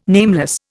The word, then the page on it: nameless: Indian